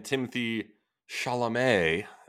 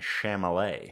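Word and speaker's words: Timothée Chalamet: It was literally only Kylie Jenner and Timothy Chalamet. Am I saying that right this time? – I do believe it. You a much better pronunciation that time than Chamolet.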